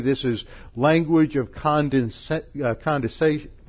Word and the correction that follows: condescension: where the eternal God, I didn't even say that right. Condescension, thank you. My tongue got tangled in front of my eye teeth and I couldn't see what I was saying. What was that again? condescension.